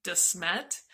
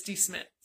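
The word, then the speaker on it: De Smet: We had to have the locals tell us how to pronounce it because I call it Desmet, but it's Desmet.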